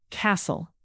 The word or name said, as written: castle